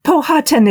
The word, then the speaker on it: Powhatan: Now, how should I say that? Powhatan? Is that the right way? The Powhatan dilemma? – Powhatan is fine. It is quite a good rendition.